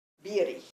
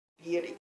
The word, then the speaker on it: Bierig: Bierich. Es B, Bierich, como B-I-E-R-I-G. Bierich. – Sí. – Y se pronuncia solo con I.